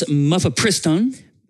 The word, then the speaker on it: mifepristone: So it's mifepristone. – Mifepristone? – It's more muh. They say mifepristone. – Mifepristone. Okay, no, well, anyway. – Muh. M-U-H is what it says. Mifepristone.